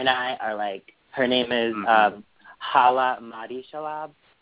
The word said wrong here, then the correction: Shaloub: Shaloub, sorry, I said his name wrong.